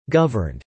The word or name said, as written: governed